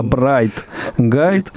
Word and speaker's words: Bright Guide: Анатолий Дмитриевич, приложение называется, обрати внимание, не Брайль Гайд, не Блайнд Гайд, а Брайт Гайд.